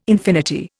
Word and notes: infinity: Indian

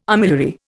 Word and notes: armillary: Indian

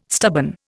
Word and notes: stubborn: Indian

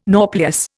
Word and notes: nauplius: Indian

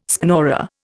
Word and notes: schnorrer: Indian

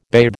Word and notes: bathe: American English

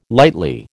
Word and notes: lightly: American English

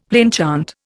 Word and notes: plainchant: Indian

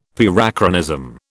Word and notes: Parachronism: British English

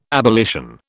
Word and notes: abolition: British English

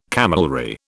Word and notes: camelry: British English

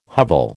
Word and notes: hovel: American English

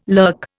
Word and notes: lurk: Indian

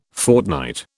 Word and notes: fortnight: British English

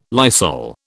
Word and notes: Lysol: British English

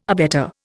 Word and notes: abetter: Indian

